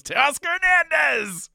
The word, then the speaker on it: Teoscar Hernandez: I did the Buck Martinez pronunciation of Teoscar Hernandez there, where I just roll the end of his first name and the beginning of his last name together. It just becomes Teoscar Hernandez!